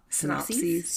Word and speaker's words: synopses: We always tease and I volunteer, but I actually never do the plot synopsises. I'm not saying that right. The plot synopses. – Synopses? – Yes. Synopses. I would say Bartok and Zosie is his friend.